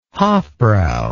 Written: hofbrau

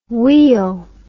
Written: weel

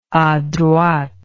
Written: adroite

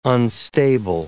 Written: unstable

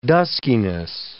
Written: duskiness

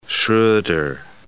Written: Schroder

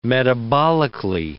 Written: metabolically

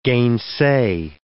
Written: gainsay

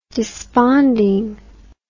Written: despondingly

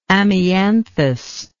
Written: amianthoidal

